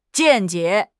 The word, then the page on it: 见解: HSK6